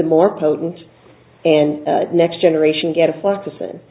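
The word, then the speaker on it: gatifloxacin: Am I saying that right? – Right. Okay. Gadafloxacin.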